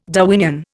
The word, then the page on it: Darwinian: Indian